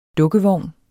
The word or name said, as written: DUKKEVOGN